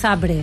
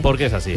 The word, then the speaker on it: Zabrze: ¿Por qué pronuncias Zabrze? – Porque es así.